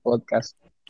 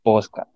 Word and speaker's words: podcast: podcasts. Que tomen en cuenta que Robert pronuncia posca. Él sabe que se pronuncia así, ¿vale? Él sabe que se pronuncia podcast, pero se le hace más sencillo pronunciar posca.